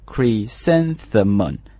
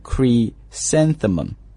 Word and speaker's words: chrysanthemum: 第一个是 chry，但是呢这个 h 不发音，所以读的是 cre 啊，然后 san 是一个词组叫 san 啊， chrysanthemum 重音在那个 san 上面，再来一遍 chrysanthemum Okay, very good.